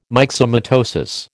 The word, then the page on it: myxomatosis: American English